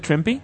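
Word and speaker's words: Trimpe: ¿Se pronuncia Trimpy? – Ajá.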